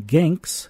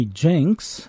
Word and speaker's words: Ginks: We began the set with Sidney Kite, K-Y-T-E, and his Piccadilly Hotel Orchestra with a vocal by the Three Ginks with Just Once for All Time from November 28, 1931. I've been guilty in the past of mispronouncing the Three Ginks as the Three Jinks, a common error.